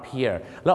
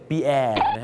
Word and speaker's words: Pier: P-I-E-R เพียแล้วออกเสียงว่าเพียนะครับบางคนนึกว่าปีแอร์นั่นมันชื่อคนฝรั่งเศสปีแอร์นะฮะ